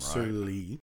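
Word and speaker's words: sur lie: Serlie. It's pronounced Serlie.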